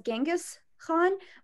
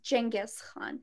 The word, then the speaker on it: Genghis Khan: The Mongols at that time, they were very well known to be tolerant of all religions, especially, I think a lot of people pronounce it as Genghis Khan, but it's pronounced Genghis Khan.